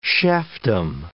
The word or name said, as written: chefdom